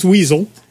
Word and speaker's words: WSL: I've edited the chapter on Weasel, the Windows subsystem for Linux, WSL, which apparently people pronounce Weasel.